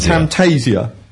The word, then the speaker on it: Camtasia: Camtasia.